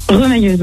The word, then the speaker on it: remailleuse: On dit, ça se prononce pas comme ça? Remailleuse? – Remailleuse, oui. – Ah, remailleuse, pardon.